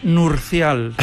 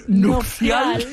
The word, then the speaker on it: nupcial: Sí, es que yo digo que hasta los 13 años pensé que era nurcial. – Nurcial. – ¿Sí? – Y claro, me cuesta decir nupcial, que lo he dicho mal. – Has dicho nupcial. – He dicho nurcial, nupcial, sí, sí.